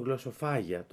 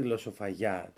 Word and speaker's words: γλωσσοφαγιά: Υπάρχει μεγάλη και συγκεκριμένα δεν ξέρω γιατί το τονίζουν γλωσσοφάγια, το λένε και λάθος και τονισμένο. Υπάρχει μεγάλη γλωσσοφαγιά τέλος πάντων μέσα στο σπίτι.